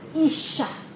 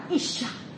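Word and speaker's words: isha (אִשָּׁה): 男人希伯来文的男人的发音是 ish，而其他那个女人的名字希伯来文的发音是 isha，所以男人是 ish，他叫女人的名字叫 isha，是不是很相连啊？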